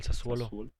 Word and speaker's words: Sassuolo: ¿Cómo se pronuncia este equipo? – El, Sazuolo, el Sassuolo. El Sassuolo. sí, correcto.